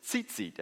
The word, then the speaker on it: tzitzit: And so these tassels, the Hebrew name for tassel is tzitzit. Everybody say tzitzit. It's kind of fun to say, isn't it? It's spelled with a T and a Z in succession. Tzitzit.